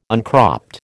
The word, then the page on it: uncropped: American English